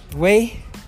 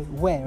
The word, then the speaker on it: Wei: Ladies and gentlemen, Wei. That's how you say it properly. Wei, right? – Yeah, Wei.